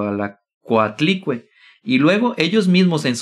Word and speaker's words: Coatlicue: Casualmente la Virgen de Guadalupe se parece a Cuatli, güey. Casual, casual. Casualmente la Virgen de Guadalupe apareció en el cerro donde los mexicas adoraban a Cuatli. Casualmente. – ¿Cómo se pronuncia? Coatlicue.